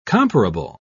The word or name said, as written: comparable